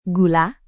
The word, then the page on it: gula: Indonesian